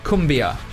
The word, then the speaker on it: cumbia: Okay, so what I wanted to kind of start the official inquiries about the music about was tell me about, and I'm almost certain that I'm going to pronounce this wrong, but tell me a little bit about cumbia. – Well, Uruguayan. We grew up with cumbia, actually. Yeah. So, yeah, it's kind of like... – First of all, you pronounce it correctly.